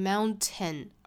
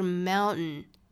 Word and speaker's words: mountain: 那这种情况呢叫做 nasal plosion，翻译成汉语叫做鼻腔爆破，也就是这个词里面的这个字母 T 的发音我们是听不到的了，不是发成 mountain，而是 mountain。